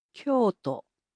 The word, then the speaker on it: Kyoto: Now, Kyoto in Japanese is pronounced as Kyoto.